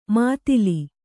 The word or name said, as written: ಮುತ್ತಲು